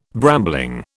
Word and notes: brambling: British English

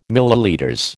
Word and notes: ml: American English

